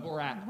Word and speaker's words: Borat: It's Borat. – Borat, yeah.